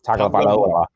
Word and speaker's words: Tagovailoa: Tagovailoa. Tagovailoa. – Nailed it first try. – Tua Tagovailoa. Tagovailoa. Tagovailoa, I think. – We're going to call him Tua T. – Let's just call him Tua. Tagovailoa.